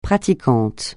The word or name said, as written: pratiquante